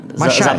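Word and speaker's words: Maçães: A čte se Masáješ nebo Mašáješ? Mas, Mašáješ. – No, Mašáš. Za, za správně. Mašáš. Za správně.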